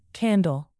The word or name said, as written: candle